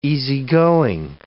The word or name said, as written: easygoing